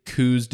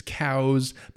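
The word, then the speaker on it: Coues: This is January coos deer in Arizona. Yes, I realize the proper pronunciation is cows, but no one in Arizona says cows, so we're just going to go with coos.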